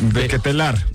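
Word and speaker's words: De Ketelaere: De Ketelar.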